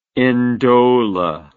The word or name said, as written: ndola